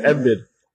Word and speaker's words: Embiid: First of all, bro. – It's Embiid.